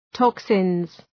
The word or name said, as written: toxins